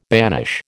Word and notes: banish: American English

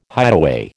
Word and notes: hideaway: American English

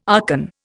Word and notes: archon: Indian

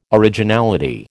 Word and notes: originality: American English

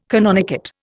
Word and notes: canonicate: Indian

